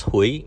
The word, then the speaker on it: Huie: First name is Gordon, last name is pronounced Huey, H-U-I-E.